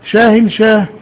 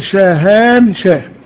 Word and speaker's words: شاهان شاه: وشاهن شاه، شاهن شاه هذا اختصار في النطق اللغوي الدارج، لكنه تقال شاهان شاه، شاهان شاه، لأن الألف والنون في هؤلاء الناس الذين هم في القوقاز والشيشان وروسيا وطالبان وأفغانستان وكله، معناها ألف لام مؤخرة.